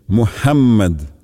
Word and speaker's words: Мухаммад: А в имени пророка Мухаммад следует обратить внимание на букву «х», которая произносится мягко, с придыханием, а также на то, что буква «м» в имени пророка произносится с удвоением «Мухаммад».